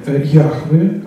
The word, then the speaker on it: Яхве: И они под согласными имени Яхве, а именно так произносится непроизносимое имя,